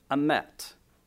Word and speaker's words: emet: Now, this word for truth comes from the Hebrew word emet, E-M-E-T, if you want to spell it, E-M-E-T.